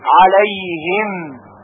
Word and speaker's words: عليهم: صراط الذین، صراط الذین انعمت، انعمت علیهم، علیهم. توجه دارید که علیهم هم باید با عین تلفظ بشه، کما اینکه انعمت هم با عین تلفظ میشه.